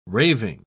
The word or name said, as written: raving